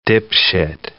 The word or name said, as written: dipshit